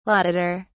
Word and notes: laudator: British English

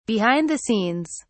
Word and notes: behind the scenes: British English

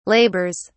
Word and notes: labours: British English

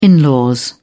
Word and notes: in law: British English

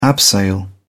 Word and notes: abseil: British English

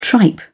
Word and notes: tripe: British English

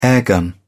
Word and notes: airgun: British English

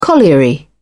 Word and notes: colliery: British English

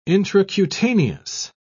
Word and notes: intracutaneous: British English

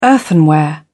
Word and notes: earthenware: British English

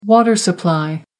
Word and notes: water supply: American English

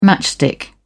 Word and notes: matchstick: British English

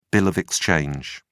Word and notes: bill of exchange: British English

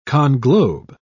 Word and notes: conglobe: British English

